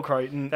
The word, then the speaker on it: Crichton: That is how that's how Spielberg it's Michael Crichton.